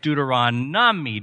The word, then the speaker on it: Deuteronomy: He says, it's Deuteronomy, Deuteronomy, Deuteronomy. And I said, no, it's adorable, but I had to correct him. It's a great book, but you have to say the name right. And so I said, no, Connor, it's Deuteronomy, Deuteronomy. So you have to say Deuteronomy.